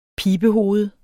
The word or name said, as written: pibehoved